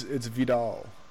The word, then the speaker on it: Vidal: you're not pronouncing it correctly. You're adding right. – an extra letter. An extra consonant. Yeah, there's no N in it. – Vindel? – It's V-I-D-A-L.